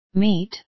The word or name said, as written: meet